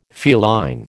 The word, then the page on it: feline: American English